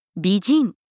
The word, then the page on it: bijin: Japanese